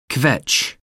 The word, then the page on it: kvetch: American English